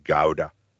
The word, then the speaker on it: Gouda: This reminds me though, before we wrap up, a few Dutch friends of mine, they educated me that it's pronounced Gouda, and I'm like, no, it's G-O-U-D-A, it's Gouda. No. It's Gouda, and now you see you've learned something new.